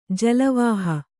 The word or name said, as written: ಜಲವಾಹ